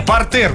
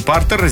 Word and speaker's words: партер: Далее, партер. Ну, конечно, не партер.